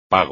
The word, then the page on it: pago: espagnol